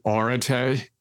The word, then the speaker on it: Areté: Yeah, I like to pronounce it Erdete. It's debatable.